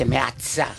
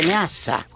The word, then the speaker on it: Meazza: No se dice Meazza, se dice Meazza.